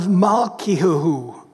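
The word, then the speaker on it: Malkiyahu: Well, then in verse 11, we have a guy by the name of Malkiahu. That's the way it's pronounced for us who understand Hebrew.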